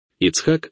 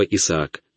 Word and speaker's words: Ицхак: Ицхак означает смех, а вы, ребята, произносите это слово Исаак, но на самом деле это Ицхак.